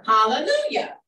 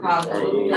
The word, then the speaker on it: Hallelujah: Hallelujah. – Hallelujah. Whether it's written in that book with the J or the I, it's still pronounced hallelujah.